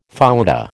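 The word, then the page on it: fouetté: American English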